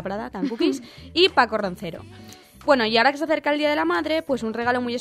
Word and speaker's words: Roncero: Bueno, pues Paco Roncero se da Roncero. Sí, lo he dicho bien.